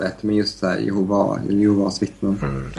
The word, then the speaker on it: Jehova: Men, men de, de själva är enligt de officiella korrekta uttalet Jehova.